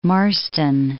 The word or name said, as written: Marston Moor